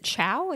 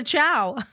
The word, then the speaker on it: Chao: Is it chow? Is that how you say it? – I think it's chow. That's how I always say it. – C-H-A-O. – Show. – Cheo. – I think it's show. I think it's like show?